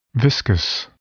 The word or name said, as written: viscous